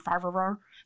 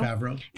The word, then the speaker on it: Favreau: But then even let's look at the context of who John, uh, I always say his name wrong, Favreau. – Favreau. Favreau.